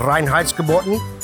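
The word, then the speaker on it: Reinheitsgebot: All Oktoberfest beer follows the German beer purity laws, which is called Reinheitsgebotten.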